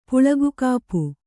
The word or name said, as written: ಪುಳುಗುಗಾಪು